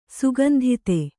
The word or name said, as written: ಸುಗಂಧಿತೆ